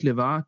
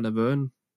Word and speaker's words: LeVar: Men altså hele hans holdning til Star Trek, altså hans måde, han udtalte LeVar Burton, John LaForge, hans navn på, det var ikke LeVar, det var Laverne.